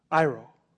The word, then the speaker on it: airo: The word literally is, it's spelled A-I-R-O. Iro.